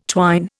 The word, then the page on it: twine: Indian